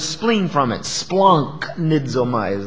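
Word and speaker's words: splanchnizomai: It's pronounced splanknizomai.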